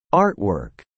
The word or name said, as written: artwork